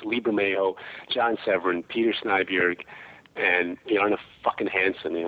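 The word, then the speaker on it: Snejbjerg: It's a graphic novel that I did a few years back with Peter Snyberg. – Nice. – The guy. – Great. – Is that how you say it? – Yes, that's how you say it. Snyberg.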